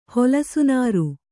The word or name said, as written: ಹೊಲಸುನಾರು